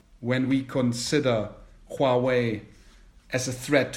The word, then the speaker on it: Huawei: So before we talk on Huawei, for the German listeners who are not too familiar with Chinese, we're talking about Huawei. Because this is the pinyin, how it is spelled, and in German marketing it is called Huawei. In Chinese, though, you call it Huawei, and I think some people might not have realized yet that we're talking about this company.